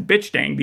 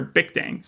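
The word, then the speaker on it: Bich Dang: There's a character in the book that's called a Bic Dang, but it's spelled B-I-C-H Dang. So in the book, while I'm reading it, I'm just reading it as Bitch Dang the entire time. I'm thinking this is like some sort of like joke on top of the fact that she's a drug dealer and she's also got all this stuff. But he pronounces it there, Bic Dang.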